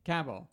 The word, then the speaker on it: Kabul: How do they pronounce that? It's in Afghanistan. – Kabul? – Cabell. – Yeah, like Henry Cabell.